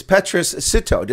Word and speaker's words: Petrus Sitho: It's Petrus Sitho.